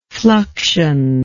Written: fluxionally

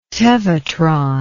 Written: tevatron

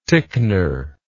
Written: ticknor

